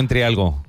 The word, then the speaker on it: Entrialgo: Don Mauro Entrialgo, muchas gracias. – Muy bien, gracias. Mi apellido lo pronuncias bien y es más difícil, ¿eh? – Pues para que veas.